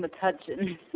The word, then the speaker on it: Metuchen: Metuchen. – Metuchen, okay. – Yeah, thank you for saying Metuchen. So if you say it enough times, I'll stop pronouncing it Metuchen.